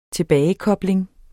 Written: TILBAGEKOBLINGEN

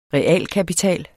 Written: REALKAPITAL

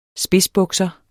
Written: spidsbukser

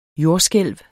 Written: jordskælv

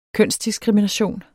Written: kønsdiskrimination

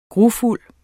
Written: grufuld

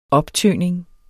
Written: optøning

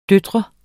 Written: datter

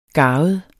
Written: garvet

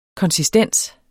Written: konsistens

